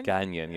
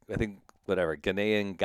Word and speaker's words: Ghanaian: You're half Ghanaian, which you say differently. Say it again. How do you say it? – Ganyan, yeah. So that's from Ghana. I thought it was Ghanaian, Ghanaian, however you say it.